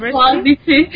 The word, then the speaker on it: Boğaziçi: Yes, Boğaziçi.